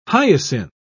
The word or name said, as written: hyacinth